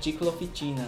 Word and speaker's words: Ciclooficina: E na verdade a pronúncia nem é assim, né? – Na verdade é Ciclooficina, né? É italiano.